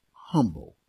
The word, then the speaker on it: humble: We're constantly encouraged by God's word to remain humble. Notice I pronounced the H. I don't know why we want to make the H silent. Humble. That's nuts.